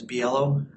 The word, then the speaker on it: Biello: Last name is Biello. That's spelled B as in boy, I-E-L-L-O. – B-I-E-L-L-O.